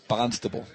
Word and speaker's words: Barnstable: Our producer, can pronounce it for us. – Barnstable. – Come on. There it is. That's it.